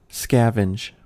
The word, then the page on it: scavenge: American English